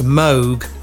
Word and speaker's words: Moog: And I have to say, if you're a real nerd, it's pronounced Moog as well.